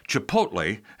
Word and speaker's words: Chipotle: Chipotle, and yes, that's how to pronounce it, I had to look it up,